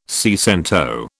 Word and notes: seicento: British English

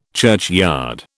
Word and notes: churchyard: British English

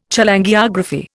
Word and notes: Cholangiography: Indian

